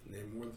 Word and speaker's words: Namor: Namor the Submariner.